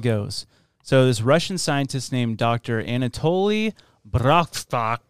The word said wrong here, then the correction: Brouchkov: I don't know how to say his name. Brouchkov.